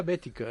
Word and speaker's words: Boetticher: Él decía Bética, ¿eh? – Sí. No, pero es que la pronunciaría a la americana. – Ya, es que nosotros decíamos Boetticher y Boetticher.